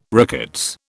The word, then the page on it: rickets: British English